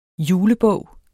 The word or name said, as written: julebog